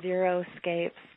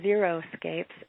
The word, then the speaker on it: xeriscape: 25 is just, I couldn't really resist throwing this picture in, avoid xeriscapes. So many people mispronounce the word xeriscape. the X-E-R-I-scape, which means dry, mm-hmm. and they say zero-scapes, and it just perpetuates a misconception about what that is.